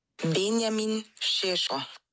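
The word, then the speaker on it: Benjamin Šeško: Sie ist halb Slowenin und sie würde mir jetzt gerne mal eine Sprachnachricht schicken mit der richtigen Aussprache. Dann sind wir auch alle schlauer. So, aufgepasst? Benjamin Shisho. Benjamin Shisho.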